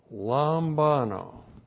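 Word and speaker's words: lambano (λαμβάνω): L-A-M-B-A-N, long O, lambano.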